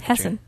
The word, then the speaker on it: Hessen: How would you say that? – Hessen. – From, there is no end. – Oh, Hessen. Sorry.